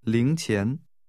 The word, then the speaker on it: 零钱: This is pronounced with two rising tones. – 再说一次。 – 零钱。